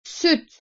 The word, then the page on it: Süt: Turkish